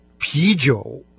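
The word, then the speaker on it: пи-джоу (啤酒): Пиво по-китайски «пи-джоу». Первая часть этого слова – она произносится со вторым тоном, это калька с немецкого «beer».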